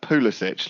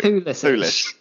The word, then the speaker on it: Pulisic: Wait a minute, is that Pulisic? Like Pulisic. Pulis. – Yeah, exactly. I think, well, that's mainly what I want to talk about. That's the official pronunciation that everyone needs to get on board with.